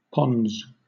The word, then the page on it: pons: British English